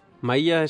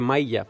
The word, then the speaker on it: Maílla: Maílla es... – Seguro, vamos, digo yo. – Sí, es una palabra curiosa. Mucha gente dice maílla, pero no, es maílla, con acento en la I.